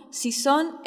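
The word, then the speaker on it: Xixón: Así, en muchos casos encontrarás una X que se pronuncia como la SH inglesa. Sanjenjo, Sanxenxo en Galicia, Gijón, Sison en Asturias, Játiva, Sátiva en Valencia, etc.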